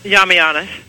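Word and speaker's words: Yiamouyiannis: Do you remember how to pronounce his name? – Yamianis. – Oh, good, right. I got it right. Okay. – You actually did get it right.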